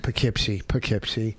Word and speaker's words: Poughkeepsie: November 17th, Laugh It Up in Poughkeepsie. – Poughkeepsie, is that how you say it? – Poughkeepsie.